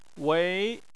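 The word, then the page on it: wei: tone 2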